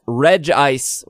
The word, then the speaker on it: Regice: And then finally, Regirock, Regice, which I verified, that's how you say it, Registeel, Latias, and Latios, Jirachi, and Deoxys are not available either.